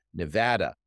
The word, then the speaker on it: Nevada: They're looking at Arizona, Florida, Georgia, Michigan, Nevada. By the way, you pronounced that correctly earlier, which is fantastic.